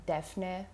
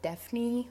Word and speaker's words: Defne: kind of mostly American people cannot pronounce the name Defne really well. They usually just go with Defni or something like that.